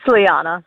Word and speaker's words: Liana: So it's not L-E-O-N-A? – No, it's L-I-A-N-A.